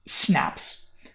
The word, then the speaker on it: SNPs: Sometimes phonetically, people call these SNPs.